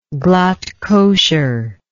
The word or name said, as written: glatt kosher